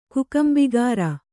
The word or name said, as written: ಕುಕಂಬಿಗಾರ